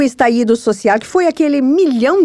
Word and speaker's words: estallido: falou assim: os chilenos eles falam estaído e não estalido, né, como nós pronunciaríamos.